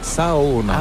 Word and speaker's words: sauna: En fait, on prononce chaque lettre, donc c'est Saona.